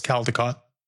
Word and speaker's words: Caldecott: Yes, that's what I saw was Caldecott.